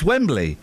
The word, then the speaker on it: Wembley: You add an extra syllable. Wembley. – I never said Wembley. Wembley. – You said Wembley. – Wembley. – You said Wembley, and you know it. It's Wembley, and you always put an extra syllable.